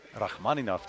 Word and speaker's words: Rachmaninoff: Rachmaninoff, just like you said it.